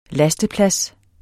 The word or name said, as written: lasteplads